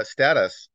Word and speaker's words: status: So status, I think, which we pronounce with a short A, sorry.